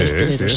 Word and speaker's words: esteleros: Dice Inma, en Canarias a los componedores de huesos se les llama esteleros. ¿Lo he dicho bien? Sí, sí, esteleros.